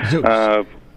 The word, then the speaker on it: Zoox: It's either Zoox or Zox. I've heard it said many different ways. So just say the zoo and drop the X on that. – Zoox.